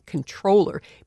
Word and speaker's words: comptroller: Narica says he never even considered saying controller because the word is spelled C-O-M-P-T-R-O-L-L-E-R.